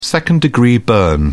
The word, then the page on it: second-degree burn: British English